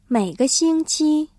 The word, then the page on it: 每个星期: měigexīngqī